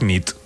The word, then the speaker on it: nit: Y noche es nit. – Muy bien. – Buena nit. Buena nit, es verdad. – Buena nit. – Buena nit, muy bien.